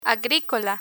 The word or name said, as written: agricola